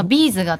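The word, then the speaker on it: B'z: で例えば、まあ、みんなビーズって言いますけれども、ビーズが正しい読み方だっていうのが、他の周囲の人たちの考え方なんですけれども、もう稲葉さんと松本さんはどっちでもいいですよ、好きでっておっしゃってるっていう、なんかそのかっこよさはあるんですけど、めちゃくちゃそのビーズなのかビーズなのかでイメージ変わりません？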